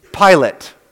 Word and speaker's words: Pilate: But traditionally, we pronounce this name Pilate, kind of like the guy in the front of the airplane.